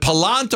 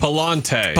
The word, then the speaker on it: Pallante: How do you say, is it Pallant or Pallante? – Pallante. – Pallante. That's what Pallante. That's what I thought it was.